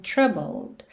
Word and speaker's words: troubled: Começando então, When You're Down and Troubled. Atenção aqui principalmente a esse troubled, que a gente já falou um pouquinho sobre ele lá na parte 2, então você não vai pronunciar essa letra E alright?